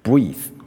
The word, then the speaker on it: breathe: 即便后面多了个一，即便尾一是不发的，但是它是有两个发音，它就是一个破音字。 Breathe 就是动词，breath 就是名词。